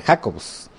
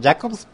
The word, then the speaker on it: Jacobs: Porque, por ejemplo, mi apellido en español realmente debiera ser Jacobs, y en inglés Jacobs, pero nosotros lo pronunciamos Jacobs porque nuestros antepasados eran judíos alemanes. Entonces la pronunciación de Jacobs es en alemán, no en español ni en inglés, pero me imagino que así ha de ser la cosa.